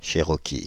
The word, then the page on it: cherokee: /ʃe.ʁɔ.ki/; France (Île-de-France)